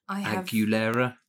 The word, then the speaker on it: Aguilera: Well, especially given that my next song is Say Something by A Great Big World and Christina Aguilera. – Aguilera. I think you're overpronouncing Aguilera. The U.